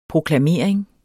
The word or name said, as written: proklamering